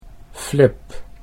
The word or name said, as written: Flip